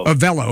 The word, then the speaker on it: Avelo: Avelo. – Okay. See, I have to put, remember we learned, what was it, the schwa when we were kids on how to, Avelo.